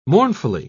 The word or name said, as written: mournfully